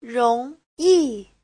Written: rong2yi4

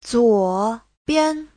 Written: zuo3bian5

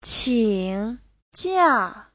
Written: qing3jia4

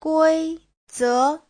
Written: gui1ze2

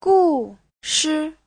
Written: gu4shi5

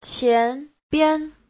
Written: qian2bian5

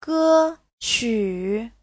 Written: ge1qu3

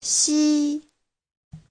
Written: xi1

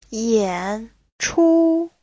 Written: yan3chu1

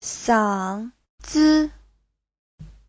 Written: sang3zi5

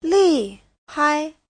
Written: li4hai5

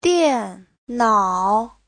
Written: dian4nao3

